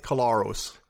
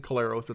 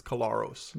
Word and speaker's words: Collaros: Edem, and it's Collaros. – Collaros? – Yeah. – Really? – Yeah. – Oh, jeez. – Ariel Zur of CGME asked Zach Collaros specifically to pronounce the surname, and he specified that it isn't Collaros, it's Collaros.